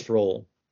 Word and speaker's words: Roald: I think it's Roald. Yeah. – Roald Dahl.